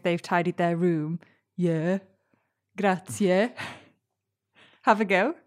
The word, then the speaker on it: grazie: Some resources recommend pronouncing it like grazie. With two syllables at the end, C and E. But that can lead you to overpronounce the ending. When natives say it, it's a short, sharp, yeah sound.